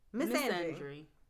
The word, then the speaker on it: misandry: Misandry.